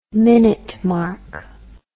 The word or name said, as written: minute mark